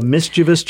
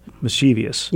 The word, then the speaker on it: mischievous: I sometimes say mischievous. – Yeah. – It's not four syllables, it's three. Mischievous.